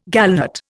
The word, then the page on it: gallnut: Indian